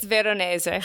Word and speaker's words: Veronese: It's Veronese. – All right. You just have to say it right. – Like you're Italian.